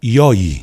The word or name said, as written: Yawyi